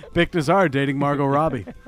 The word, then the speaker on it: Robbie: How do you say her name? – Robbie. – Robbie, there we go.